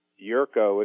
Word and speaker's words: Jerko: Dr. J-E-R-K-O. – Dr. Jerko? – That's Yerko, if you don't mind. He's a little sensitive about it. A lot of people mispronounce it.